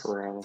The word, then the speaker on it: Corral: I think I'm going to butcher his last name, but Matt Carroll from Ole Miss. – Corral. – I've seen Corral. – I've seen people. Huh, Corral. See, I knew I'd butcher it. – Like the restaurant.